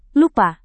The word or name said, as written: lupa